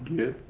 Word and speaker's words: Gid: Gid? – Gid Graham.